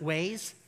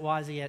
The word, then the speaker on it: Waze: So I was using Google Maps back then, and so I switched from Google Maps to Wazi, some people pronounce it Waze, but we pronounce it Wazi at, at our house just because it's funner. It's just funner to do Wazi, you know, funner.